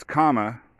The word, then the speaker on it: Kamala: And then Kamala Harris. You know, everyone mispronounces her name. Anyways, it's like comma. You know, you got like a question mark, a period, and a comma. It's Kamala.